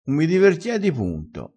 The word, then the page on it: divertiedi: dialettale